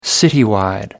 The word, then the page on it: citywide: American English